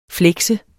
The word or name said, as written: flekse